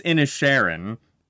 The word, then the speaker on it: Inisherin: It's Inisherin, I believe.